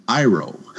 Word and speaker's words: Iroh: But I did start leveling a Pandaren shaman named Ariel, pronounced Iroh. Like in the Avatar, The Last Airbender series.